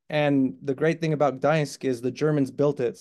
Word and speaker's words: Gdańsk: Gdańsk. Is that how you say it? – Gdańsk.